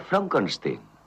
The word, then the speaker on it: Frankenstein: No, se pronuncia Frankenstein.